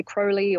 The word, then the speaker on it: Crowley: So we've talked about this house in relation to Loch Ness and Aleister Crowley. So I'm going to go with Crowley. That's how Australians would pronounce his name. I know some British people might say Crowley or something a little different, but I'm going to keep tripping over myself.